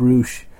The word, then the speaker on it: Rush: It's spelled Rush, but it's pronounced Roosh.